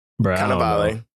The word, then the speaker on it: Cannavale: Cannavale.